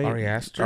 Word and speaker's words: Ari Aster: Ari Aster.